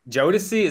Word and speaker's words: Jodeci: Oh, Jodeci? – Jodeci, is that how you say it? – Yeah, Jodeci.